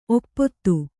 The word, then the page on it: ಒಪ್ಪೊತ್ತು: oppottu